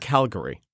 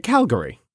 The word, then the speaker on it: Calgary: I said Calgary. Calgary. Like Calvary. Like where Christ was crucified. – It's like one syllable. Calgary.